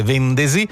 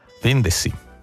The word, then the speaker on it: vendesi: Nella pronuncia, tra l'altro, quella S è sorda, ma dalle nostre parti tende a essere sonora, quindi noi tendiamo a dire vendesi, anche se dovrebbe dirsi vendesi.